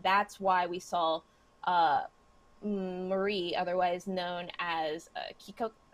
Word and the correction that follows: Kinoko: I apologize. I'm never going to pronounce that. – Kinoko. – Kinoko, thank you.